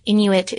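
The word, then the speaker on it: Inuk: "And a single Inuit person is an Inu, two syllables.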